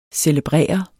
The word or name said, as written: CELEBRERE